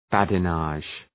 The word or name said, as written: badinage